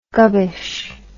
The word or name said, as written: gubbish